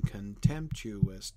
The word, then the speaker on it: contemptuous: Contemptuous, right? Four-syllable word with the stress on the second syllable.